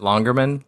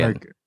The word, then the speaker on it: Lonergan: Uh, oh, is it is it Longerman? – Was it Longerman? Lonergan. Lonergan.